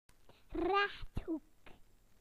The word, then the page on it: rectangle: British English